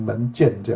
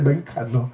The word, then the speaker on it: 门槛: 因为当时我在做朗读版的时候，那这个帮我朗读那个人都念成门剑这样子。我的这个门剑门槛我记忆非常的清楚啊，因为它都是念成门剑这样子。